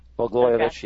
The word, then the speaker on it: Blagojevich: Look at the case of our government, Blagojevich, and excuse me if I mispronounce this. – Blagojevich, yeah.